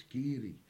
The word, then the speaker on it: Kiri: Mistake a lot of singers make with this is pronouncing Kiri as Kiri. – Oh, yes. – It's Kiri.